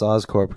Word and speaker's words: Oscorp: That's Oscorp because it's yeah, because it's a corporation. Corporation, right? – And yeah, not, it is not Corps. – A it is not yeah. an Army Corps.